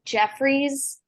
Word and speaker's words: Geoffreys: And also there's a place called Jeffries, but it's spelled Geoffreys.